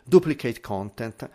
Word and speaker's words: duplicate content: Um, fixing, quindi fixare, eh, re, diciamo, rimettere a posto il uh, duplicate content, Dupli, il duplicate con, scusate ho sbagliato la pronuncia, duplicate content.